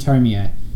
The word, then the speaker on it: Tomie: I'm your host, and today we are doing a character profile episode about a strange girl named Tomie. That's how I've been told to pronounce it.